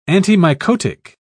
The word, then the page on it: antimycotic: British English